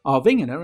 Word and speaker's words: Arvingarna: Arvingarna, and that is how it's pronounced, walked the jury scores, getting a maximum 56 points, but in raw votes it finished just over 13,000 ahead on total televotes.